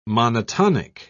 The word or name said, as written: monotonic